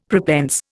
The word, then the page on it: prepense: Indian